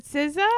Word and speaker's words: SZA: how do you say, SZA? Is it SZA? – Oh, yeah. Mm-hmm.